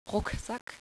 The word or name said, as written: Rucksack